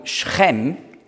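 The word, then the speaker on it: Schem (שכם): Sichem, hebräisch ausgesprochen Schem, heißt Schulter.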